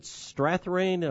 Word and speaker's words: Strathairn: David Strathairn, I believe is the way his name is pronounced, 64, played Edward R.